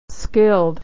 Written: skilled

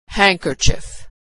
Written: handkerchief

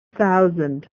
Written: thousand

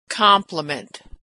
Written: compliment

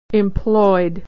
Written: employed

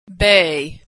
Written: bay

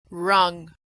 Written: rung

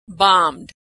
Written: bombed

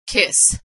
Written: kiss

